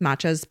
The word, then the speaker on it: matcha: matcha.